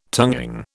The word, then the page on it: tonguing: British English